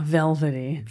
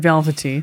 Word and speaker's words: velvety: Velvety. – Velvety. – Velvety. – Velvety. – Yeah, you can pronounce the T. That's fine. Velvety.